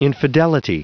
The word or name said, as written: infidelity